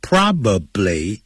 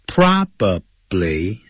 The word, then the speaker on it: probably: Probably 这样念的不对的，叫 probably 啊，probably 这样念，P R O B A B L Y， probably， probably 可能会昏倒，faint， faint 不要念成 faint， faint aiding。